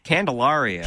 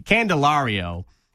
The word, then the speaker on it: Candelario: Candelario. – Thank you. Thank you. Candelario, 23-year-old.